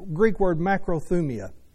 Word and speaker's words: makrothumia: It's the Greek word makrothumia. Let me just spell it. It's M-A-K-R-O-T-H-U-M-I-A. M-A-K-R-O-T-H-U-M-I-A. Makrothumia.